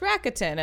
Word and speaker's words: Rakuten: I think they've done some ads where they like kind of show you like it's pronounced Rakuten as part of their gimmick.